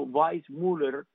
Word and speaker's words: Weissmuller: que estoy diciendo Weissmuller, que es lo correcto de decirlo en alemán, porque su apellido es alemán.